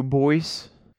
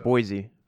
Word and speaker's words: Boise: You're going to Boise, or is it Boise? How do you pronounce it? Boise. Boise. Isn't Not there Boise Bo- too? – No. Well, there's a Bo- Boise State.